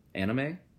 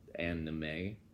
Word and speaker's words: anime: It's called anime. It's one word. – Anime. – Anime. Yeah, like A-N-I-M-E.